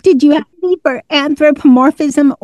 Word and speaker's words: anthropomorphism: It borders on, let's see if I can say this right, anthropomorphism. – Yes. Yeah, you're right.